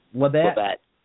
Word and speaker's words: Labbett: And Rebecca Romaine, Ben Glebe, Mark Labett, L-A-B-B-E-T-T. That's Labett, right? – Yep. – Am I pronouncing that? – Labette. – Okay, thank you so much for correcting me.